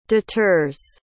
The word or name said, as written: deters